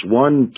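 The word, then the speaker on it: on (ἄν): There's one key word in the Greek pronounced on, A-N, which I'll talk about just a little bit, but you have to go there and read it.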